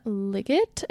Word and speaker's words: Liggett: The next one that I read was called The Gracier by Kim Liggett, I think is how you say her last name. Liggett, L-I-G-G-E-T-T. The Gracier.